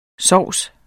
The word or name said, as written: sovse